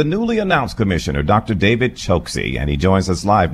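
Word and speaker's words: Chokshi: It's Dr. Dave Choksi. – It is Choksi.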